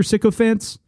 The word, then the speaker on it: sycophant: I'm like how you guys are Sixer sycophants. Is that how you pronounce it, Taylor? Sycophant? – Yes.